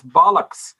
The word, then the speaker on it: bollocks: And you mispronounced the word, it's bollocks.